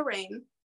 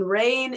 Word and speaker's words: Rayne: And So I go by Sophia Rayne on every streaming platform. But the way that my name is spelled is S-O-P-H-I-A, and then Rayne is R-A-Y-N-E.